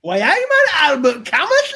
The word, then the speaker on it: Camus: It's Albert Camus. – Camus. You called him but Camus. – But it's spelled C-A-M-U-S.